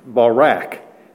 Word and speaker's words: barak: We would spell it B-A-W if you want to spell things in English phonetically from the Hebrew, B-A-W hyphen R-A-H, with the accent on the R-A-H, or R-A-K, sorry, R-A-K, barak.